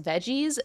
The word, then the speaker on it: Vegz: One that I go to an embarrassing amount is, I think it's pronounced Veggies, V-E-G-Z, in Admo.